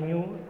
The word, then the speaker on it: GNU: que se pot pronunciar GNU com l'animal.